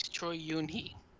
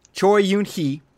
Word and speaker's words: Choi Yun-hee: There you have famous actress Choi Yuni. Did I say that right? Choi Yuni? – There should be a space. Choi Yun-hee. – Okay. Choi Yun-hee, who is a famous actress from South Korea.